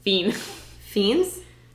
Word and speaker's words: Fiennes: Fiennes. It's pronounced not how you think it is.